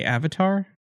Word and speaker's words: avatar: Do you guys know the word abattoir? – I don't. – Are you trying to say avatar? – Yes, I'm mispronouncing avatar.